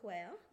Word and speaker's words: Quaoar: So, just so I can keep track, you're talking about Quaoar, is that how we pronounce it? – Quaoar, which is, you're being deliberately vague, but a body in the asteroid belt.